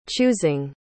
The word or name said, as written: choosing